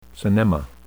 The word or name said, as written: synnema